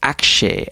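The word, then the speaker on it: Axé: That's spelled, be it by the way, just like axe, A-X-E, but there's an accent on the E.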